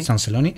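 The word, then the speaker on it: Sant Celoni: Es un artista de Sanceloni, ¿lo pronuncio bien? – Sanceloni, que se estrenó en solitario en el 2011 con Endavant Continu, hasta llegar a La Luminosa.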